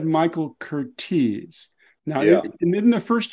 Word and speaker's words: Curtiz: So I call him Curtiz, but how he pronounced his name was Cortez.